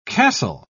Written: castle

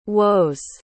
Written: woes

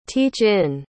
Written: teach-in

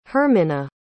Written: Hermina

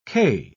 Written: quai

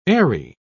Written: eyrie

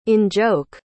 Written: in-joke

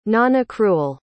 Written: nonaccrual

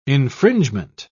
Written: infringement

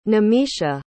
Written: nimisha

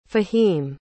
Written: Fahim

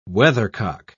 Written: weathercock

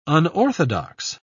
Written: unorthodox

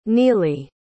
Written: nealie

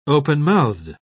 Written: open-mouthed

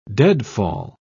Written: deadfall

